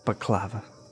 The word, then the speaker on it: baklava: It's both. Baklava. It can be both.